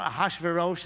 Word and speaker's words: Ahasuerus: It turns out Ahasuerus is the correct pronunciation as far as this non-Hebrew-speaking person can muster. But you know what my favorite pronunciation is? Ahasuerus. Ahasuerus. Look at the spelling. You'll see I'm right. It's Ahasuerus. Because this man is a hazard to us.